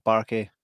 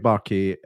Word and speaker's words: Buarque: That's how you say his name, eh? Buarque. – Yeah, that's right. Confirmed by watching a previous interview where the guy just asked him, how do you pronounce this? So, but actually, you can kind of work it out. Just by looking at it, it's Buarque. So yeah, Eric Buarque is Bruce Willis's stand-in stroke stunt double, has been for the last, 15 years, something like that.